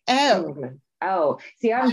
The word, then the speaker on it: Englund: think it's like, is it pronounced just England? I, it goes L U N D. – Yeah. It's like England.